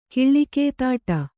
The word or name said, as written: ಕಿಳ್ಳಿಕೇತಾಟ